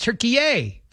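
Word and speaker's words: Türkiye: Apparently, it's Turkey-ay, Fred.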